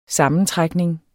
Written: sammentrækning

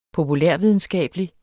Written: populærvidenskabelig

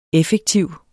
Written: effektiv

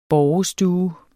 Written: borgestue

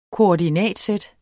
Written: koordinatsæt

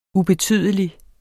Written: ubetydelig

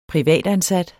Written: privatansat

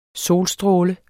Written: solstråle

